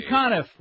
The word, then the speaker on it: Conniff: Ray Conniff, rhymes with Goniff.